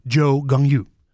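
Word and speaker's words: Zhou Guanyu: En mijn tip is Joe Gangyu. Zeg ik het goed op in? – on you, Ja, best goed.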